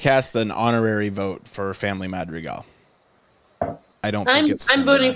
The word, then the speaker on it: Madrigal: I'm not too drunk to say this correctly. What is it, Jalen? – Family Madrigal.